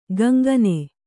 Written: ಕಂಗನೆ

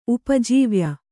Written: ಉಪಜೀವ್ಯ

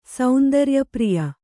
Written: ಸೌಂದರ್ಯಪ್ರಿಯೆ

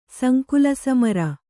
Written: ಸಂಕುಲಸಮರ

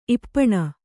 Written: ಇಪ್ಪಾನೆ

